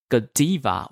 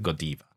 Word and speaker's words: Godiva: Godiva or Godiva is okay to, yeah, pronounce. – Both okay. To be honest, both okay.Yes, but in Belgium, if you want to be very precise, in Belgium, they pronounce it Godiva.